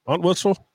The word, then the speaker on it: Entwistle: Entwistle.